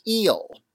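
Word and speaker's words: El: His followers assumed the surname Bey, like the Turkish honorific, or attached the suffix "eel" to their actual surnames, spelled E-L, like the Arabic article L, but pronounced "eel," and used as an appendage after a proper name to signify Moorish identity.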